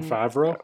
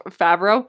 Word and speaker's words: Favreau: What I had seen online is John, I'm going to how do I say his last name? The director? – John Favreau. – Favreau? – Yeah.